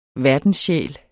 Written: verdenssjæl

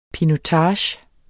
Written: pinotage

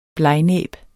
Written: blegnæb

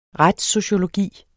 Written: retssociologi

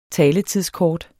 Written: taletidskort

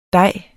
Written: dej

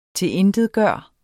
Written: TILINTETGJORTE

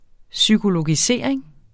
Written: psykologisering

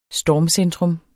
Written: stormcentrum

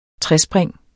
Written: trespring

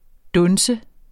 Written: dunse

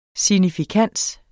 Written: SIGNIFIKANSEN

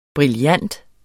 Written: brillant

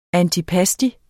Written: ANTIPASTI

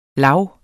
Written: lag

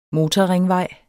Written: motorringvej

